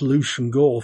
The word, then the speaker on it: Leveson Gower: However, this period's most important Tory in economic matters was Granville Leveson Gower, which is pronounced Lucian Gore for some reason, 2nd Earl Gower, and from 1786, 1st Marquess of Stafford.